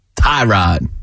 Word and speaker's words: Tyrod: Tyrod.